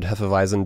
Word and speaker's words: Hefeweizen: I'm going to say it properly with a V instead of the W. This is an unfiltered Hefeweizen beer with grapefruit juice and natural flavor.